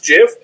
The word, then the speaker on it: GIF: If he says it's pronounced Jiff, it's pronounced Jiff.